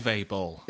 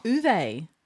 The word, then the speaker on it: Uwe Boll: Uwe Boll. – Uwe. The W was a V.